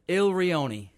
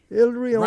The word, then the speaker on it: Il Rione: Owner, how do you say it, owner? – Il Rione. – Il Rione. – Rhymes with bologna.